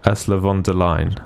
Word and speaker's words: von der Leyen: Ursula von der Leyen.